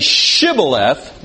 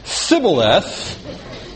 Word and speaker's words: shibboleth: If the person said shibboleth, then he said it right. But if he said sibboleth, then he knew he was an Ephraimite.